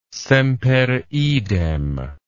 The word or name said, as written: semper idem